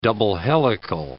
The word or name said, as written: double-helical